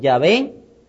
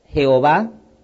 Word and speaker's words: Yahvé: ¿Cómo pronunciaría este nombre? YHWH. Por eso muchos los pronuncian Yahvé, otros Jehová. Lo más correcto sería como Jehová o Jehová, con Y.